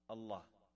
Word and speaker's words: Allah: It's easy to be pronounced on the tongue. A-double-L, exhale with love. Allah.